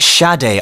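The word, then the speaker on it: Sade: So upon a little bit of research, I found out that Smooth Operator, the artist behind it, is actually pronounced Shade. I love that. That's such a unique pronunciation of it as well.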